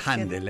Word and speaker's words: Händel: De hecho, le dicen Handel, ¿eh? – Handel. Le dicen Handel,